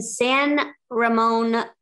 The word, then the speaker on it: San Ramon: Okay, let's talk to Natasha in San Ramon, California. Am I saying that correctly? – Yes, you are.